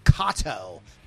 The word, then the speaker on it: Kaato: They're a band called, and I pronounced their name wrong, and they corrected me. They're called Kaato, K-A-A-T-O, from Australia.